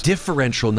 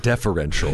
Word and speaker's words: differential: Differential, not deferential. – Yeah, correct. – Okay. – Yes. – Important pronunciation there.